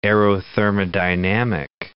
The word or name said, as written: aerothermodynamic